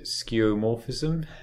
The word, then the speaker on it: skeuomorphism: There was, for a long time, they were spearheading, I'm going to butcher the pronunciation of this, is it skeuomorphism? – Yes.